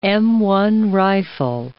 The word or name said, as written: M1 rifle